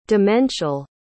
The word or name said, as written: demential